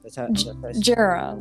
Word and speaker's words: Jira: Jira, like Jira. Sarah with a J.